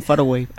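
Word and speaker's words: Far Away: Em, el Over the Hills and Faragay. No sé si lo he dicho bien. Over the Hills and Faraway.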